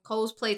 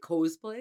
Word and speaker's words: cosplay: Basically, they make like a makeshift costume, cosplay type of thing of Anaith's. – You say cosplay? – I did say cosplay. That's not how you say it. – Cosplay.